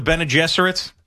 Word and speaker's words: Bene Gesserits: Oh, the Bene Gesserits. Is that my saying that correctly there, Fleegs? The Bene Gesserits? – I think so, yeah.